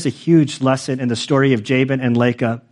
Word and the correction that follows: Laban: Jacob, Jacob and Laban, of like, that there, did I say it wrong again? Jacob and Laban.